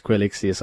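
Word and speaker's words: Quilixios: I was corrected this morning for pronouncing his name wrong. Quixilius. – You were calling him Quixilius, yes. – Yes, it's Quixilius. – And I was going to do the same. – Yes, it's Quixilius. I had the X and the L in the wrong place.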